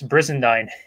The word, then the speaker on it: Brizendine: It's Brizendine. It's one of those words that just rolls right off the tip of the tongue.